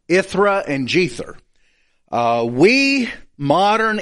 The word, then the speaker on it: Jether: The letter J used to be pronounced as the letter Y. So it would be Jether, which is a lot closer to Ithra.